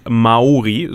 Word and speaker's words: Maori: Ich wusste zum Beispiel nicht, dass die Maori, so wird das übrigens ausgesprochen, mit langem A.